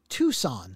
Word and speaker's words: Tucson: I said, but just so you know, since you work for Hyundai, it's pronounced Tucson. – Yeah. – Like the number two and then son, like Tucson, Arizona.